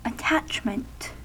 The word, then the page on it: attachment: /ə.ˈtætʃ.mənt/